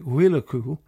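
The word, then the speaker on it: Muirchú: His name is Murchu, but it's pronounced in Gaelic Wilacu,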